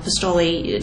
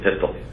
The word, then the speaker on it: Pistole: Last year, FBI Deputy Director John Pistole testified before Congress that... – Just as a matter of usage, I think he pronounces it Pistole.